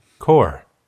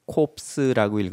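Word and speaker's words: corps: core, core. – 군단. 이거를 할 때는 ps를 읽지 않는다는 걸 꼭 아셔야 돼요. 이거를 core라고 읽어야지 corpse라고 읽으면 안 됩니다. 프랑스 말에서는 자음으로 끝나는 단어들은 자음을 읽지 않아요.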